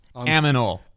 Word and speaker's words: Aminal: Aminal. A-M-I-N-A-L. Aminal. – I'm okay. So glad you said that right, because I was going to totally get that wrong.